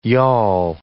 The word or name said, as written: y'all